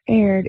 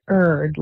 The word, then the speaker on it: erred: I had an evidence professor who just beat it into our brains that it's not erred, it's erred, like burr, and I hate to say it that way, but that's the correct way to say it.